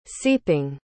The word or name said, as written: seeping